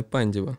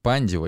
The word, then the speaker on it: Пандев: В этом сезоне Академия Пандева или Академия Пандев, как, видимо, правильно произносится это название, заняла седьмое место в чемпионате Македонии и гроссмейстерски повторила результат прошлого года.